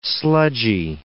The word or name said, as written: sludgy